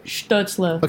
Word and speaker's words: Stützle: What's his actual pronunciation? I want to make sure I get it right. – Stützle. – Okay, Tim Stützle.